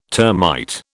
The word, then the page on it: termite: British English